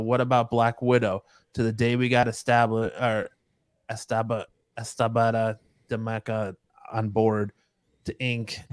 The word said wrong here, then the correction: Elisabetta D'Amico: What did you just say? – I butchered that name so bad. – Elisabetta D'Amico. – Elisabetta. There you go.